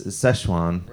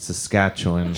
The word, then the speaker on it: Szechuan: Everyone is saying the hot thing now is Szechuan. – Right. – So I misread it, and I thought it was pronounced Saskatchewan.